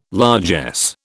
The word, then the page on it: largesse: British English